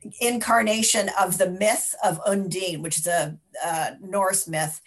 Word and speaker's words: Undine: And so the other one that was an international film was Undine. Is that correct? – They actually pronounce it Undina, but okay. I would say Undine.